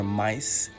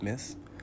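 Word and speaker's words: Myss: Today's quote is from the book Invisible Acts of Power by Carolyn Miss, or Mice. It's spelled M-Y-S-S. I've heard it pronounced Miss, Mice, Mace. I'm going to go with Miss. And the quote is from the ancient Greek philosopher Epictetus. E-P-I-C-T-E-T-U-S.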